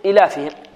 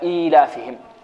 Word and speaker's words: إيلافهم: خافوا أن يقرأها القارئ يقول إلافهم. فهنا جاءوا بيا صغيرة، صغيرة الحجم، ووضعوها في هذه الكلمة كي ينطقها ماذا؟ أو كي ينطقها قارئ القرآن. إذن ننطقها هكذا إيلافهم، لإيلاف قريش إيلافهم.